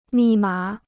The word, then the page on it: 密码: mì mǎ